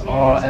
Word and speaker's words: Ares: It's spelled Ares, Ares, isn't it? – Yeah, which is Mars.